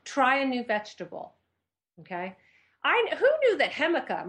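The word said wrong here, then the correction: jícama: am I saying that right? Hemica. How do you say that? – Jicama? – Nope. Jicama. I say, loser.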